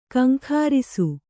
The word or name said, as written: ಘೀಂಕರಿಸು